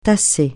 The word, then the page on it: tasser: francais